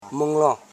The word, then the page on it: minron: min.ron; Koro